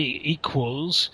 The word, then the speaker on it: equals: So when you say equals, think of a W in the middle.